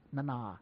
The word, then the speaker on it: Nana: I now know how to pronounce her name, because I spoke to her, and she said Nana.